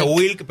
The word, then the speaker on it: Wlk: Y se pronuncia Wilk, pero es WLK.